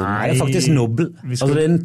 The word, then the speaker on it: Nobel: Jeg tror det uttales Nobel. – Er det Nobel? Ja, faktisk Nobel.